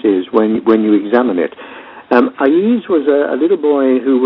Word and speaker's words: Aiz: I pronounce his name Aiz as well, so we're either both wrong or we're both right.